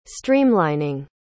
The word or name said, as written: streamlining